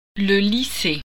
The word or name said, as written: lycée